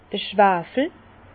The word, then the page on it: d'r Schwafel: Bas-Rhin; Herrlisheim